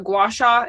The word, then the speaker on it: gua sha: You also do this gua sha.